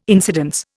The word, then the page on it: incidence: Indian